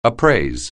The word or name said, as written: appraise